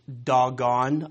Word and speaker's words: Dagon: Now, Dagon is actually pronounced Dagon. I know you think I'm making that up, but that's true. You can look this up in any Hebrew dictionary. It's actually pronounced Dagon. I'm going to pronounce it as Dagon because that's one of the more common English pronunciations, Dagon or Dagon, but it actually is Dagon.